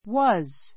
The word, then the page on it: was: 弱形 wəz; ワズ; 強形 wɑ́z; wɔ́z; ウォズ